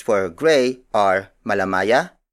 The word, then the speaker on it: malamaya: The last native color is malamaya.